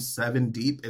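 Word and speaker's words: Sevn Deep: Last thing I'm going to say, and as far as like recommendations for music, there's a black queer artist named Seven Deep. It's spelled S-E-V-N. – Yes. D-E-E-P.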